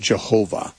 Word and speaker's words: Jehovah: and pronounce it Jehovah, with a J sound at the beginning.